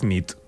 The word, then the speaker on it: nit: Y noche es nit. – Muy bien. – Buena nit. – Buena nit, es verdad. – Buena nit.